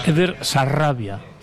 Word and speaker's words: Eder Sarabia: A ver, pronuncia bien, Raúl. Es Eder Sarabia.